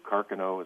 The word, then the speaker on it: Carcano: I've got two Carcano, is the proper pronunciation.